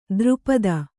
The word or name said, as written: ದ್ರುಪದ